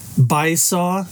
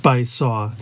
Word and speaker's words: Baizuo: It's pronounced Baisaw. And it's spelled B-A-I-Z-U-O. Sorry, the COVID just killing me here. Phonetically, it looks like Baizu, but it's technically pronounced Baisaw.